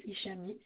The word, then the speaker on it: Ishami: Hicham, mais ça s'écrit Ishami, M comme Michel. – Y a que vous dans le logement? – Il y a mon fils. – C'est le même nom? – Oui, Ishami, c'est ça.